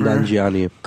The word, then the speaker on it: Nanjiani: Kumail Nanjiani, whatever. – Nanjiani.